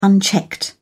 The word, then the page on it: unchecked: British English